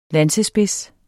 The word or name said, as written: lansespids